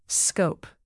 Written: scope

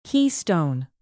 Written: keystone

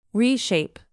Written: reshape

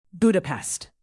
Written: budapest

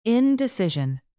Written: indecision

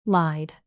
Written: lied

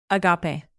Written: agape

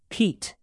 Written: peat

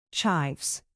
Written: chives

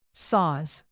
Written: saws

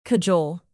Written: cajole

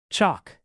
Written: chalk

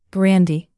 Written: brandy

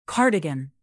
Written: cardigan

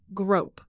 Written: grope

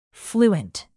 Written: fluent

